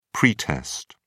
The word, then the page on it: pretest: British English